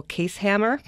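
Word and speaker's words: Kaeshammer: And Friday, February 8th, going to the Mardi Gras with, you pronounce his name for me? – Michael Casehammer.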